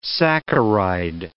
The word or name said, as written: saccharide